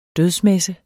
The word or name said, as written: dødsmesse